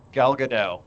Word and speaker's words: Gadot: Gal Gadot.